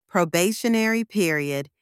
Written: probationary period

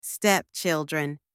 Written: stepchildren

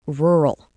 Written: rural

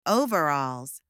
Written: overalls

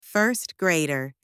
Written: first grader

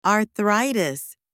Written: arthritis

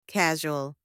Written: casual

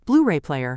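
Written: Blu-ray player

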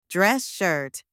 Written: dress shirt

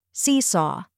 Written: seesaw

